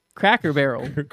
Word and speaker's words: Cracker Barrel: I said Crackle Barrel. – Crackle Barrel? You don't even know how to pronounce it. – Crackerel. – Cracker Barrel.